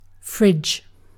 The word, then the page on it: fridge: British English